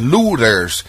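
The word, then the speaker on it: Luther: La familia de los Luthers, esa es la pronunciación alemana de los Lutero, de donde viene el joven Martín Lutero.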